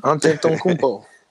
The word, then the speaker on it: Antetokounmpo: No, you did it right. Antetokounmpo. Say it again. – Antetokounmpo. – Yeah, that's it. – I haven't heard it better than that, honestly. – Antetokounmpo.